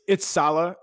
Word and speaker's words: Saleh: Do you think they would even think about going to a defensive coach, maybe like the 49ers defensive coordinator, Robert Saleh? Am I saying that correctly? – It's Saleh, and nobody knows how to pronounce that.